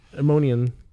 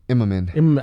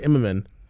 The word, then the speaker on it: Immonen: It was Warren Ellis and Stuart Immonen. – Immonen. – I always say it wrong. Stuart Immonen.